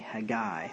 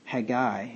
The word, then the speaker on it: Haggai: And Haggai, or Haggai, I think it's actually Haggai. Let me check the pronunciation on that. Haggai, yeah. Do you know that? According to my Cambridge, anyway, for whatever that's worth.